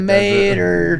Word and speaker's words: Mater: Do you guys know that Mater rhymes with Tomater? – I just got so mad.